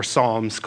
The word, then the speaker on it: Psalms: We landed on Draw Near, Praying the Psalms, but I had really heavily pitched for Psalms, colon, the P is silent.